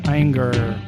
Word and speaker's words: Ayinger: No, they have a they have a hard hard G in German. – Ayinger.